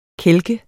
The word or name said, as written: kælke